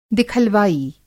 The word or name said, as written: दिखलवाई